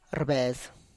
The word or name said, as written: rrbes